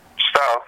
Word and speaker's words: Stalets: Stalets, yep.